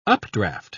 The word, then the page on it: updraft: British English